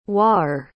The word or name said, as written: waer